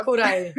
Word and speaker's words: Kuraj: Yes, Karolina Kuraj.